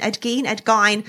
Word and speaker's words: Gein: It's well very into the Ed Gein. I believe that is the pronunciation. Ed Gein.